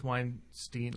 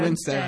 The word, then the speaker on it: Winstead: a sequence where um, Mary Elizabeth Weinstein Winstead. Winstead.